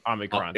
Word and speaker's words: Omicron: how do you say it? Is it Omicron? – Or no. Just say Omnoms. – Omicron. – Omicron. – Yeah, Omicron with no N.